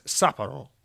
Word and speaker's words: Sapporo: Sega Sapporo, it's pronounced Sapporo.